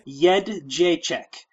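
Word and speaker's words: Jędrzejczyk: Jedrzejczyk.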